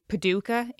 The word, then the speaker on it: Paducah: So Charles had moved to Paducah in Kentucky. I think it's pronounced. I apologize if that's wrong. – Give me the spelling. – P-A-D-U-C-A-H.